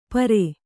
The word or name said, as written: ಪರೆ